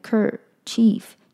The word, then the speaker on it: handkerchief: Handkerchief 就是手帕嘛，所以它前面会有一个 hand H-A-N-D，后面有一个 kerchief K-E-R 就是 ker chief 是 C-H-I-E-F chief，所以整个单字就是 handkerchief embroider。好，那第三组单字是 compel 是动词，激起、引发的意思。那虽然我们常常听到 compel 是当做强迫。